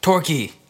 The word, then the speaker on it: Torquay: Oh, gosh. Torquay. – Ooh, hey. Nice one. Good pronunciation.